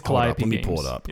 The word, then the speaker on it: Calliope: Is it Calliope? I know I'm off. – I think that's right.